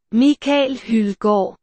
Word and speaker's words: Mikael Hildegard: Well, it's spelt Michael Hildegard, but of course it's pronounced like this. – So hang on. – Mikael Hildegard.